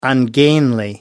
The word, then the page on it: ungainly: American English